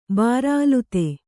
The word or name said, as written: ಬಾರಾಅಲುತೆ